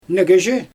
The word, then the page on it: negezhi: Xyzyl